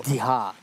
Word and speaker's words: Vidhya: Ona to píše V-I-D-Y-A. – But we pronounce it V-I-D-H-Y-A. – Ale my to... – Vidha.